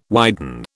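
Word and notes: widespread: British English

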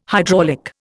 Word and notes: hydraulic: Indian

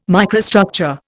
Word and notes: microstructure: Indian

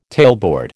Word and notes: tailboard: American English

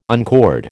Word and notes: uncord: American English

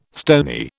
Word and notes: stony: British English

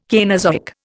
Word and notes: Cainozoic: Indian

